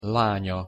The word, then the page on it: lanya: Hungarian